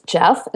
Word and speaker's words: Geoff: His name is Jeff, and it's spelled G-E-O-F-F.